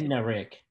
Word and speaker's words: Bednarik: I'm going to go with Chuck Bednarik. Is that how you pronounce it? Can you please? – Bednarik.